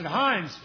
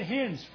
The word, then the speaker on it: hinds': But old Ted didn't pronounce it hinds' feet. He said hens' feet.